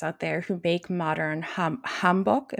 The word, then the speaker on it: hanbok: Yeah, it's the O is almost like a O. It's like a rounder O. Hanbok. Bok. Like hanbok. – Yeah, but not like, you don't have to overthink it. But it's like a hanbok. So it's a little more rounder O sound.